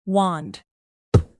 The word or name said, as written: wand